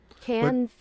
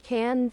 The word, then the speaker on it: Cannes: Cannes Festival. – Oh, yeah. That's a silent S on Cannes Festival. – Cannes Festival. – But you will hear people say Cannes.